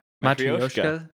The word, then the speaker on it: Matryoshka: Matryoshka.